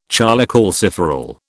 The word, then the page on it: cholecalciferol: British English